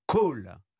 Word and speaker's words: Kohl: Et dans ces 40, on parle du phénomène call. Alors, c'est quoi call ? – Ça s'écrit K-O-H-L, comme Helmut Kohl.